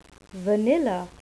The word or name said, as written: vanilla